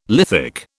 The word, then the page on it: lithic: British English